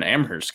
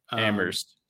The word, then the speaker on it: Amherst: And you're an Amherst guy, so that's. – Oof. Bad pronunciation there. – Amherst.